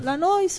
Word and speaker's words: Lanois: Lanois o Lanois? C'è chi chi lo pronuncia all'inglese e chi è la francese.